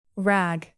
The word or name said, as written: rag